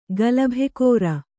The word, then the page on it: ಗಲಭೆಕೋರ: galabhekōṛa